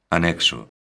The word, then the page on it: anexo: galega